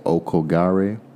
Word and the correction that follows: Aokigahara: Okoka, the mountain in Japan? – Aokigahara. – Aokigahara, the Sea of Trees.